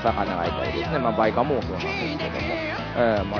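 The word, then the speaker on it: 梅花藻: バイカモ、梅の、花の藻と書いてですね、バイカモが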